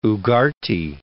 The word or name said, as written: Ugarte